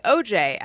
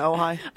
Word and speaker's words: Ojai: Justin Chan says, no matter how many times I read oh hi, I always intuitively say OJ out loud. No, I say it right.